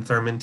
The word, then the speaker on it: Thurman: Uh, my name is spelled E R I K, uh, and then Thurman T H U R M A N comics.